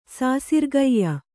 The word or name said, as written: ಸಾಸಿರ್ಗೈಯ